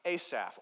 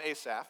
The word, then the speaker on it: Asaph: This song was written by a guy named Asaph, or Asaph, you could say, but we kind of like the long A in English, so we'll call him Asaph.